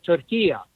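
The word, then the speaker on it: Türkiye: In the Arabic language, it's called Turkiya. So you could say Turkiya. It's not like a long A at the end. So it's a short A at the end. Very simple.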